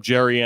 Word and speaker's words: Gerry: But you can look up Gerry Anderson. It's spelled like Jerry, but with a G, so it's weird.